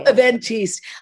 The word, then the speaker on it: Eventiste: Oh, Eventiste. I made it up as a joke and now I know. Everybody. – Eventiste.